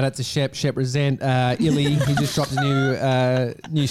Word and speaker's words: Illy: Illy. I-L-L-Y.